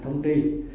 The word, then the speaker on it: thông tri: Thông tri sứ mạng, thông tri, chữ này chữ trí chứ không chữ tri, à, trí là làm để bố trí đó, nhưng mà đọc là thông tri.